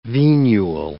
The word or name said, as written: venule